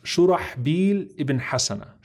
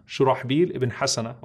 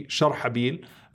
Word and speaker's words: Shurahbil Ibn Hasana: His name is pronounced Shurahbil Ibn Hasana. Shurahbil Ibn Hasana, radiyallahu ta'ala anhu. And it can also be Sharhabil, according to some of the scholars.